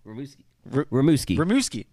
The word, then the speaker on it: Rimouski: Rimouski.